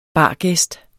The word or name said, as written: bargæst